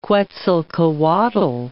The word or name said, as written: Quetzalcoatl